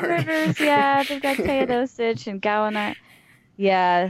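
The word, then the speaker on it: Teodosić: I think it's pronounced Teodosic, but Teodosic, yeah.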